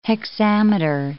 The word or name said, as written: hexameter